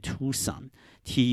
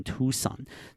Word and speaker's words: Tucson: 它的英文念 Tucson，T U C S O N，就这样就要念 Tucson。